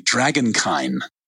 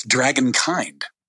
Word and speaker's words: Dragonkyn: And so, and it's funny because a lot of readers were pronouncing it Dragonkyn. And if you add a D, then, you know, it's Dragonkind, you know, like humankind. And so at this point, I don't care how it's pronounced. I'll take either.